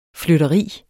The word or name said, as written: flytteri